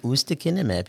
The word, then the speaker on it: ustekinumab: Ustekinumab. He did good. – Okay. – Oh, okay. Yeah. – I've been practicing. Okay. It took 30 minutes or so, but ustekinumab.